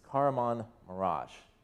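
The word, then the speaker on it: Kahramanmaraş: And then that name that everybody was struggling to pronounce is Karaman Maraş.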